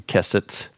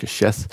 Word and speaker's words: keshet: And the transliteration spelling is Q-E-S-E-T. It looks like it should be keset, but it's pronounced keshet.